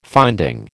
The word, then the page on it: finding: American English